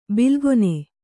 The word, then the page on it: ಬಿಲ್ಗೊನೆ: bilgone